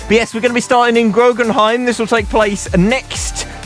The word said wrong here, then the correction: Groningen: Is that how you pronounce it? – Groningen? – Yeah, that sounds better.